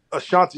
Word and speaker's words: Asante: You said Ashanti. – Asante, yeah. So that's spelled, you know, with a T-I. Mine is T-E, but my great-grandmother, my great-great-grandmother's last name was Asantewa. So A-S-A-N-T-E-W-A-A, which is the feminine version.